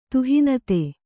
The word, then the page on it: ತುಹಿನತೆ: tuhinate